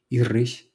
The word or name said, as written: irris